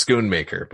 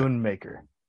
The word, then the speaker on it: Schoonmaker: I still don't know how it's said. – I think it's Schoonmaker, but anyway. – Schoonmaker.